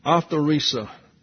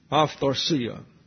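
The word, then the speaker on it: aphtharsia (ἀφθαρσία): The word imperishable is the Greek noun, let's see, aphtharsia, the accent's on the second last syllable, aphtharsia.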